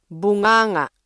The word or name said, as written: bunganga